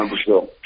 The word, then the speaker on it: Brazile: Donna Brazile,